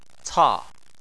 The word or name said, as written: ca4